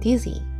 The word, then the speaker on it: dizzy: getting better なんですけれども、めまいはですね、英語で dizzy, I feel dizzy, I'm feeling dizzy でめまいがすると表現します。 dizzy の名詞は dizziness ね、えー、 dizzy ですね。発音は z Z の Z の音です。え G ではないですね。 G ではなくて Z です。